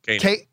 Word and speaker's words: Koenig: but Walter Coing is that how you pronounce Chekhov's name? – Walter anyway. – Koenig. Koenig. Okay. – Yeah. Koenig.